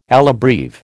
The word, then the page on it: alla breve: American English